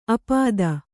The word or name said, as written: ಆಪಾತ